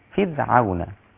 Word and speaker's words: فرعون: فرعون. رقق الراء.